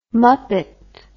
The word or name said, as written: Muppet